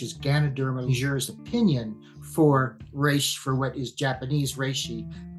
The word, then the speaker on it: reishi: This is the, I'm going to mispronounce it, but the reishi. – Yes, reishi.